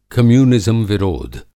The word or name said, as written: कम्यूनिज़्म विरोध